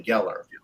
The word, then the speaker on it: Gellar: it's Sarah Michelle Gellar, but it's really Geller. Everybody's got their own pronunciations.